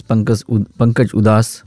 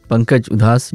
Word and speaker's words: Pankaj Udhas: Now people mispronounce his name as Pankaj Udhas, and Udhas kehte hain ki ye seth the isliye ghazal gaate the. Nahi, Pankaj Udhas, Udhas, inka surname hai, and inka bichhav हमारे बीच से हुआ 26 फरवरी 2024 में.